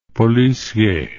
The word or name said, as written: policier